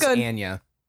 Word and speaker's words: Anya: It's Anya Taylor-Joy. Yeah. – It's pronounced Anya.